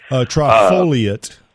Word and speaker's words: trifoliate: One, you've been mentioning trifluora orange. I think that's how you pronounce it. – Trifoliate. – Trifluoriate. That's right. Yeah, foliate. Okay.